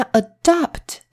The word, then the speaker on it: adopt: 好，那再来呢，有一个跟它很像的字，把中间的 a 改成 o。发音会变成 adopt，adopt。刚刚那个是 adapt，这个是 adopt。好，那 adopt 这一个很像的字，这个动词是什么意思呢？